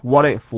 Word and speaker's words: what if: What if, what if, what if? WH 的 H 不发音， T 跟 I 呢只要连在一起念软的 What if, what if, F 这边是完整的，因为 F 呢是跟后面的字不冲突，不用消音， They fall 是完整念法，所以我们只看 What if 这个地方， What if they fall? – What if they fall?